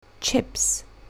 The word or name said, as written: chips